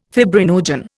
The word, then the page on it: fibrinogen: Indian